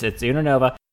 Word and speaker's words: Unova: And then you have Unova, which I can't pronounce that correctly. No, it's Unova.